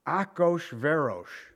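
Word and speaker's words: Achoshverosh (אחשורוש): the son of Ahasuerus. Now, this guy's name has been mispronounced so many times it's not funny. Do you know what it really is? Achoshverosh.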